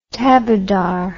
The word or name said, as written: taberdar